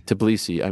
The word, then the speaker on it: Tbilisi: Tbilisi.